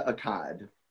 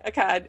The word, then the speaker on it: Akkad: It's actually pronounced, Mustafa Akkad. – That's Mustafa, okay. – Yeah. – Akkad, yeah.